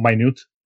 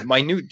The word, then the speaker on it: minute: By the way, just to correct you, Corey, it's pronounced minute, not minute. And minute details, no? – The minute yeah, details.